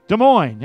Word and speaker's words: Des Moines: Des Moines, yeah, that's it.